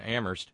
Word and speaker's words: Amherst: Note coming from Terry in Amherst, where only the H is silent.